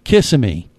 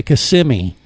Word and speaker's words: Kissimmee: It's like, you know, we're in Orlando and we have this area of town down by, you know, Touristville called Kissimmee. That's what everyone says because it looks like it's Kissimmee, but you actually say a Kissimmee. It's an Indian pronunciation.